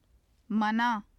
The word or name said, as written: manaa